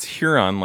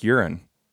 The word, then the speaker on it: Huron: No, like when people when people will pronounce Huron like urine.